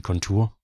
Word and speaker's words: Contour: Yeah, I would use the same pronunciation, okay. Beovision Contour. That's the way I say it. I said it from day one when we invented the name or found the name here. We use Contour.